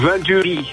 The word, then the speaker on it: Venturi: Autofabrikant Venturi. Zeg ik dat goed of is het gewoon Venturi? Wat vind jij? – Nou, Venturi. Het is Frans.